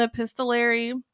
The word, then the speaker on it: epistolary: It's an epistolary. I always feel like I don't say that word right. – No, you said it right.